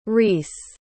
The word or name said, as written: reese